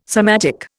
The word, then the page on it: somatic: Indian